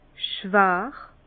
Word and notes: schwar: Bas-Rhin; Herrlisheim

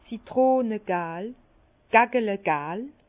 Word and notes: citrönegal: Bas-Rhin; Herrlisheim

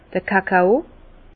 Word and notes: d'r Kakao: Alsatian; Bas-Rhin; Herrlisheim

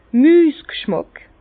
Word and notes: Müsg'schmàck: Bas-Rhin; Herrlisheim

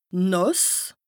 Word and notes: nàss: Bas-Rhin; Strasbourg

